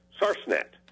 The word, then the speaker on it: sarsenet: S-A-R-S-E-N-E-T. Now, that second S, according to the wordsmith, could also be a C. And although it looks like you might pronounce this word with three syllables, actually you're supposed to say sarsnet, not sarsenet.